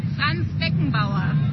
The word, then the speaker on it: Franz Beckenbauer: Franz Beckenbauer.